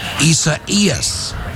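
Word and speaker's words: Isaias: Traditional Spanish yes. – pronunciation. – Okay. – I is pronounced E. – Yes. – A is pronounced ah. – Yes. – So if you sound it out. – Yes. – Isa Eas. – Isa Ian. – Isa Eas. – Isa Ea. – Eas. You got to put the S on the end.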